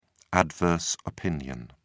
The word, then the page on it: adverse opinion: British English